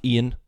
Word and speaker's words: Eoin: It's by an Irish author named, it's spelled Eoin, but it's called pronounced Ian by some fucking strange coincidence. Ian Colfer.